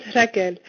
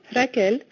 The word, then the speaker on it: Raquel: my name is Raquel, and they would say Rach. So today, I happily embrace the Spanish pronunciation of my name, Raquel, and I also acknowledge that it's Rachel in English, and in many different countries, we have many different ways to pronounce my name.